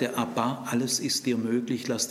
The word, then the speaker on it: Abba: Und diese neue Anrede heißt aramäisch Abba. Ton liegt auf der zweiten Silbe.